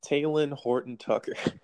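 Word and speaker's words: Talen Horton-Tucker: How do you pronounce it? – Talon Horton Tucker.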